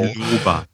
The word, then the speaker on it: biloba: 100 Milligramm Gingo Balboa, äh, Bingo, sag ich auch schon Balboa. – Bilabo, das, ich habe das, hab das 25 Jahre lang falsch gesagt, das muss mich da, ja. Äh, muss mir das abtrainieren. Bilboa, genau. Bilbao. Biloba. – Biloba, genau. Biloba. Genau. Gingo.